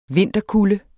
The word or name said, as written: vinterkulde